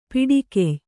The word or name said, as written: ಪಾಡುಗಾಯಿ